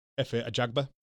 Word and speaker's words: Efe Ajagba: And then also I always have trouble pronouncing his name. – Efe Ajagba. – Ajagba goes up against Ali Aaron Demirizin.